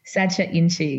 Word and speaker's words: Sacha Inchi: Sacha Inchi.